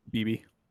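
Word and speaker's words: Beebe: Cooper Bebe, BB? How do you pronounce it? – BB.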